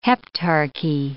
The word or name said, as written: heptarchy